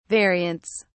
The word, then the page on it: variants: British English